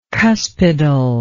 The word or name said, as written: cuspidal